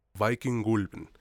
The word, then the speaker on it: Vikingulven: Concuerdo contigo y ahora sí, pues no alarguemos más el asunto, iniciemos con la película Viking Gulben. Así es como la pronuncian en Noruega al parecer,